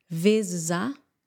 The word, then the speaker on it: vezes: Vezes, não é vezes, vezes, ok? Eu sei que é uma palavra difícil, às vezes, de pronunciar. Vezes, vezes, mais uma vez com a palavra A de seguida. Vezes A, vezes A.